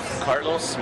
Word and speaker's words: Carlos: Carlos Morales.